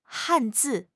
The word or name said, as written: han4 zi4